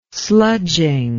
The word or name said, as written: sludging